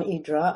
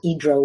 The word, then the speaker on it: Hydra: Hydra, first of all, when you live there, it's called Hydra, which is the pronunciation that had differentiates us from the tourists, because the tourists always say Hydra.